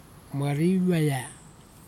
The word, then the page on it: mariveia: kwamera